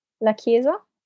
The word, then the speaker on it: La Chiesa: La Chiesa.